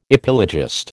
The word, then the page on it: epilogist: American English